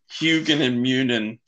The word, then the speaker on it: Hugin and Munin: I'm gonna need you to repronounce that, please. – Oh, you're a Hugin and Munin? – Yeah. – Sorry. – That stressed me out. – Sorry. Hugin and Munin Publishing.